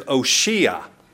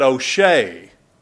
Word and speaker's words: Oshea: His name is Oshea. Now some people pronounce that O'Shea, but he wasn't Irish. He was a Hebrew. It's Oshea.